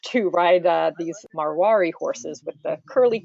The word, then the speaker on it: Marwari: Um, and one other um, one other chapter was about the Mar Marwar, and I'm not sure if I'm pronouncing this Marwari. – Marwari, yeah. Um, mm-hmm.